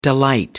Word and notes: delight: American English

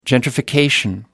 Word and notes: gentrification: American English

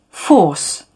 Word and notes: force: British English

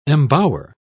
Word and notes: embower: British English

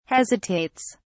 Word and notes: hesitates: British English

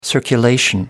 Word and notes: circulation: American English

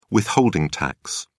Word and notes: withholding tax: British English

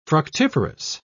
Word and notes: fructiferous: British English

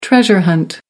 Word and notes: treasure hunt: American English

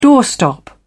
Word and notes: doorstop: British English

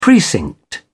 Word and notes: precinct: British English

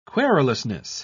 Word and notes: querulousness: British English

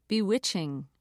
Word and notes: bewitched: American English

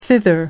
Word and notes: thither: American English

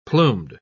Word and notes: plumed: British English

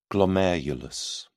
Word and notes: glomerulus: British English

